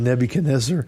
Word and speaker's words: Nebuchadnezzar: That's the phonetic pronunciation. The biblical pronunciation is Nebuchadnezzar. Exactly. That's what we're going with. That's what everyone's done.